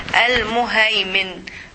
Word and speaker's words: Al-Muhaymin: Now we will move to the name of Allah, the next one, which is Al-Muhaymin.